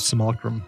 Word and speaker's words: simulacrum: No, there are lots of references and things, but saying it's a Cimmerillion? I thought it was. I don't know why I thought Simulacrum.